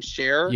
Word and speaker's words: Scherr: And uh, so far, I think the talent we know for sure that are going to be there is Adam how do you say his last name? Adam Scherr.